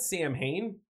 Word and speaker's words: Samhain: And they were calling it, well, it's spelled Samhain, but it's pronounced what, Drew? It was fucking, I know you got Wiccan friends that say this shit. Samhain. – Yeah. – I'm yeah. Not. Fucking Samhain. You know they get really pissed at you if you pronounce it Samhain and not Samhain?